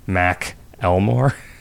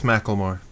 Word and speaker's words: Macklemore: Is his name, like, Mac Elmore? – No, it's just Macklemore, like Cher, only with more syllables.